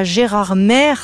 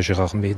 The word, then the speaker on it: Gérardmer: Attention, ne prononcez pas Gérardmer, ça irrite les habitants, les Géromois.